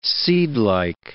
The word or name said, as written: seedlike